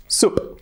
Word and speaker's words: soup: Por ejemplo, tenemos group, grupo. Y esa, todo el mundo decimos group, ¿no? Group. Por lo tanto, sopa, pues será soup.